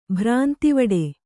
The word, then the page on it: ಭ್ರಾಂತಿವಡೆ: kannada